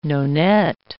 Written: nonet

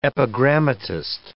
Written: epigrammatist

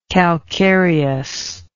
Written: calcareousness

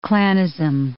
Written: Klanism

